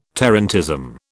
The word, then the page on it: tarantism: British English